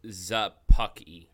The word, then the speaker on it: Szapucki: His last name is spelled S-Z-A-P-U-C-K-I. – I have the pronunciation guide open. – Thomas Sapuki. That's probably your best bet. – Sapuki. – It's Zapucky.